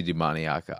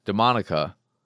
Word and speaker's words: Demoniaca: It has to be Demoniaca. I don't like it. Because I want to say Demonica, but that's not how it's spelled. It's Demoniaca.